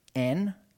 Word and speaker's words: en: The word translated for is actually the Greek preposition en, how we would phonetically say it, and where we get the word in.